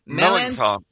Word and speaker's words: Melanchthon: Philip Melanchthon.